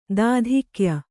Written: ದಾಧಿಕ್ಯ

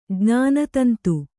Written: ಜ್ಞಾನತಂತು

